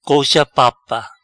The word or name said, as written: Colcha Papa